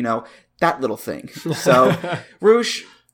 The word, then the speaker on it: Rush: But anyway, Ricardo, today we're going to be looking at the themes of one luchador in particular, El Toro Blanco Rush. Not Rush, not the Canadian rock band, not Leo Rush, not Courtney Rush, not Jeffrey Rush. It's Rush. That's how it's pronounced.